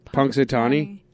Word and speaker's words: Punxsutawney: Punxsutawney, Pennsylvania. – How do you say it again? – Punxsutawney.